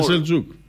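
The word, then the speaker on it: Selçuk: Με σι είναι, αλλά προφέρεται Σελτζούκ.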